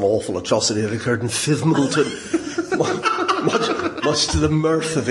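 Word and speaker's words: Fivemiletown: was told by the locals that the pronunciation of the village of Five Mile Town, which was in the news quite a bit, was Fivmilton.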